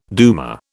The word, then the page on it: duma: British English